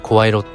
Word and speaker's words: 声色: 声色って読